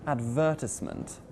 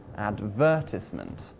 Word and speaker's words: advertisement: The word advertisement has four syllables, and we place the stress on syllable number two. Advertisement. Not on syllable number one, advertisement, or syllable number three, advertisement. Ooh, no, no, no, no, no, no. That would just sound weird.